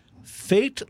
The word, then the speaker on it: Fait: Tennessee's Fait La Force Brewing, and Fate is spelled. Uh, F A I T, like fait accompli.